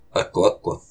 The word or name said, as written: Aquq